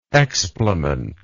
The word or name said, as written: explementary